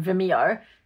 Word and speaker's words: Vimeo: we get the snapshots and we watch it on Vimeo. Am I saying that right? – Yeah.